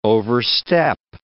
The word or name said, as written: overstep